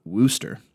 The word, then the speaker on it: Worcester: And somehow people in Massachusetts pronounce it Worcester. How the hell you get Worcester out of W-O-R-C-E-S-T-E-R, I don't know.